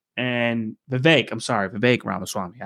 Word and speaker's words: Vivek Ramaswamy: Vivek Ramaswamy.